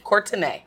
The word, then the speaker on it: Courtenay: Courtenay.